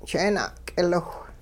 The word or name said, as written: jeena'k'iliwh